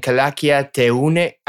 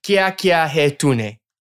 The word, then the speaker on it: Kya Kiya Hai Tune: Well, everybody, look out for these new collabs and singles and Kya Kiya Hai Tune. I've completely butchered that. – Sorry. Okay, I'm going to make you say it properly. Kya Kiya Hai Tune. – Kya Kiya Hai Tune.